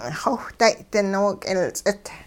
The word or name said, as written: xowhday-ding nook'ilts'it